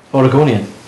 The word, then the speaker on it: Oregonian: Portland, or Oregonian, how do you pronounce that, says... – Oregonian. – That's the one.